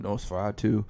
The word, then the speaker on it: NOS4A2: It's spelled N-O-S-4-A-2, Nosferatu.